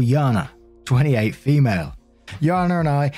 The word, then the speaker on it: Jana: And just before we get into it, this one does have a pronunciation of a name, J-A-N-A. And I know it can be pronounced a couple of ways, either Jaina or Yana. I'm going to go for Yana in this one.